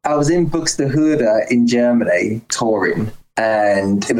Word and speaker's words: Buxtehude: But the song, Dietrich Buxtehude. – Buxtehude. Yeah, you said it right.